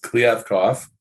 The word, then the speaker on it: Kliavkoff: George Kliavkoff. If I said that right, I'm going off the Pac-12 press release guide to pronunciation. So we'll go with Kliavkoff.